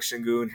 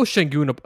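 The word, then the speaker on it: Şengün: Yeah, and I owe Sengun an apology, by the way. I've been pronouncing his name Sengun on the past few podcasts. And I've heard both. I've heard Sengun, yeah.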